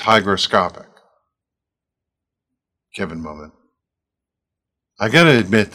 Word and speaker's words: hygroscopic: Hygroscopic starts with a high, H-Y, but it's pronounced in the beginning like hi.